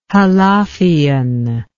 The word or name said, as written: halafian